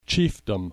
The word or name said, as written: chiefdom